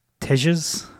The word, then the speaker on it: Tejas: T-E-J-A-S. You guys want to have a go at that? Tejas? – No, the J would be pronounced a H probably.